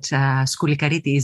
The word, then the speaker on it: Skoulikiriti: You did very good. And actually, you used the perfect Greek pronunciation because many of you would guess that Skoulikiriti is a Greek name, very Greek name.